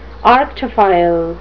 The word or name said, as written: arctophile